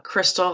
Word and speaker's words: Christal: You can find me on Instagram at I am Crystal Hall. My first name is spelled C-H-R-I-S-T-A-L.